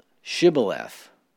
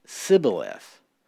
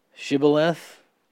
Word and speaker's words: Shibboleth: But verse 6, so apparently, the Ephraimites could not pronounce Shibboleth. They had learned and heard it Sibboleth.